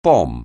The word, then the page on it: bomb: anglais